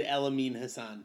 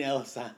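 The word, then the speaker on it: Amin Elhassan: You said Elamine Hassan. – Amin El Hassan.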